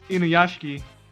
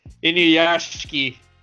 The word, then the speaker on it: Inuyashiki: I think it's pronounced Inuyashiki. – Okay, Inuyashiki. There we go.